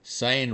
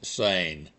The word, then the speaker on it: Seine: Until the current Olympic coverage, I thought the Seine River winding through Paris to be pronounced Seine. Well, TV people, correctly I'm sure, are calling it Seine.